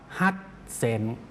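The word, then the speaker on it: ฮัต เซ น (八千): แต่เขา 8,000 เขาไม่ได้พูดว่าฮัจจิเซนครับเขาจะออกเสียงว่าฮัตเซนครับ 8,000 ออกเสียงว่าฮัตเซน 8,022 ฮัตเซน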